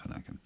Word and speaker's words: Hohenecken: It's spelled Hohenecken, but it's pronounced Honecken.